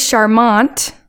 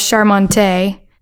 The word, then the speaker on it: charmant: The word is charmant, which means charming. And when Dave was writing it, he couldn't remember what the pronunciation was for that word because it has an E at the end. So he wrote it as charmante. And then later when he realized that wasn't the correct pronunciation, he was like, screw it, leaving it in there.